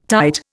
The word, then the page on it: tight: Indian